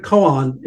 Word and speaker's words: koan: The word koan is actually the Japanese pronunciation of the Chinese term kung an, literally public cases.